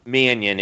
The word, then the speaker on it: Mannion: Mannion is how it's pronounced.